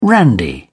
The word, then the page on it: randy: British English